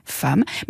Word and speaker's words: femme: Donc femme vient du latin femina, qui progressivement s'est prononcé femme, puis femme. Mais là encore, les lexicographes ont tenu à maintenir le lien avec l'origine latine, donc le premier E, même s'il se prononce A.